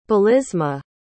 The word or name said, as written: belisma